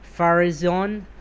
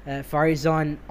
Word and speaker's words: Farizon: Its heavy-duty division, it's Horizon, but you take Hor and put it Far, so Farizon Auto. So again, it's a mishmash of Far at Horizon, and you mix it together. That's how I'd pronounce it. That would be like Farizon Auto.